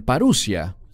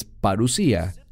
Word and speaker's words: parusía: Algunos la pronuncian parusia, pero es parusía.